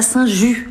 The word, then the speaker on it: Saint-Just: On est à Saint-Just. On dit Saint-Just, mais ça s'écrit Saint-Just.